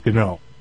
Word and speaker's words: GNOME: Like Gnat, Gnot, and Gnuncle. – And Gnipgnop, which is a great game from the early 80s. But officially, it is GNOME.